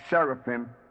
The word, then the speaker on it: Seraphim: And the angelic choir are the Seraphim, spelled like seraphim, but pronounced as in Hebrew, Seraphim.